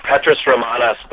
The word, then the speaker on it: Petrus Romanus: Well, we are talking to Chris Putman, the co-author of Petrus Romanus. Am I saying that correctly? – That's right. Petrus Romanus, The Final Pope Is Here.